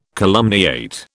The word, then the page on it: calumniate: British English